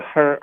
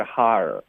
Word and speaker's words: hard: Hard 不是 her 是 hard。